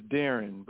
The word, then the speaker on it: Darron: Now, I'm sorry, I keep pronouncing your name, Darron. Is it Darron or Darion? – It's Darron, but it's all right.